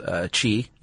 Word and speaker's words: qi: And that your body has an energy running through it called qi, even though it's spelled Q-I.